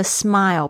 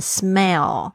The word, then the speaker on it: smile: 这边我想要特别提醒一下大家，你们的那个 smile 不要再念成 smell，因为我已经听到大概百分之有十个里面有九个学生会念成 smell。